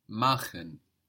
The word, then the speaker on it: machen: That's spelled M-A-C-H-E-N. And here we have friction on the C-H, machen. – Machen. – So it's only soft when we have an I before, like ich, mich, nicht.